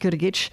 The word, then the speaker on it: Grgić: We pronounce it Grgić Vina. The only difference in spelling is that there's no H, but there's an accent over the C that gives it that CH sound.